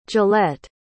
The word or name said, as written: gillette